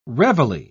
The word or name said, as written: reveille